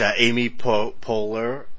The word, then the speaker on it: Amy Poehler: Yeah, which Amy Poehler, that's how you say her name.